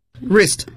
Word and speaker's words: wrist: wrist 表示手腕，但是读的时候 w 是不发音的， t 也不能发音， wrist watch 读起来呢比较不容易，大家还是记 watch。